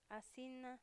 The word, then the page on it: atsina: a'zina